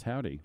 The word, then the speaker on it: Havdi: Spelled Havdi, pronounced Howdy.